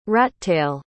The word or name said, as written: rattail